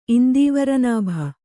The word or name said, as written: ಇಂದೀವರನಾಭ